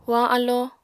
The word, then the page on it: hoañ alló: apatani